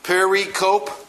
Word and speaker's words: pericope: Okay, pericope, but it's pronounced pericope. It's quadrisyllabic. Isn't that a great word, quadrisyllabic? It means it has four syllables.